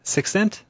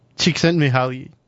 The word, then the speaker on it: Csikszentmihalyi: Sixcent? – Csikszentmihalyi.